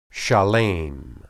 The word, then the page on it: shalem: hebrew